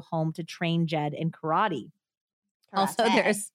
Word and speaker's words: karate: And then as soon as he says karate, he goes karate every single time. – Well, that is the correct way to pronounce it with Japanese accent.